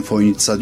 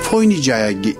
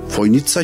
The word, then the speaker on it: Foynica: Ama çözemediğim şey, telaffuz olarak ben Foynitsa diyorum, öyle duydum. Fakat gelen misafirlerin hepsi o C'yi S olduğunu bilmiyor. TS olduğunu bilmiyor ve Toynica'ya işte gelmekten şeref duydum gibi şeyler zikrediyor. Merkeze bir yazı yazdım. Dedim ki Foynici olarak yazılan kelime telaffuzu Foynitsa şeklindedir. Düzeltilmesi.